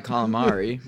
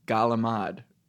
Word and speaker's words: calamari: It's not fried calamari, right? – Correct. – It's galamad.